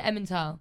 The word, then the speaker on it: Emmental: Yeah, and we had some Emmental cheese. Is that how you say it? Emmental.